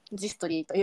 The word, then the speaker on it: ジストリー: 夜なきゃりというかジストリーの説明を同じにちょっとさせていただこうと思うんですけれども、えっと、株式会社ジストリーという会社にと勤めております。えっと、ザ・ストーリーと書いて、これ文字反転してるんですかね。はい、ザ・ストーリーと書いて、ジストリーと読みまして、えっと、看護師のえ看護師さんが自分らしいキャリアを実現する社会を、えー、作るっていう思いが創業の背景にあります。